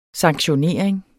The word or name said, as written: sanktionering